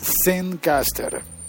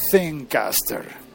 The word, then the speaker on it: Zencastr: Se llama Zencaster para los españoles de Europa, Zencaster. Se escribe con Z, como, como Zen, como la filosofía Zen, bueno, filosofía no, como la cultura, como eso que yo no sé qué es, Zen.